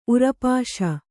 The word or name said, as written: ಉರಪಾಶ